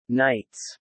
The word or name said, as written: knights